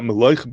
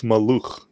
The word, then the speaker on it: Maluch: the Yavetz says that the way to read this word is not Meloich, but it's Maluch. That's the way the word should be pronounced,